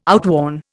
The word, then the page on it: outworn: Indian